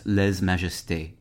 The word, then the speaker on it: lèse majesté: In modern French, lèse majesté is pronounced lèse majesté.